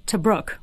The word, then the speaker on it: Tobruk: Hell Run Tobruk, right? – You did.